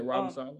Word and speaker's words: Saleh: Can these other guys, what's his name, Robert Saleh? Am I saying his name right? – Yeah, Robin Sala.